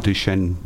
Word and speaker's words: Duchenne: I'd like to find out a bit more about Fraser in a moment, but first, could you tell us about Duchenne muscular dystrophy?